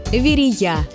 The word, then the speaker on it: Верея: Проблема в ударении. Очень хочется поставить его на второй слог. Но правильный вариант только один – Верея.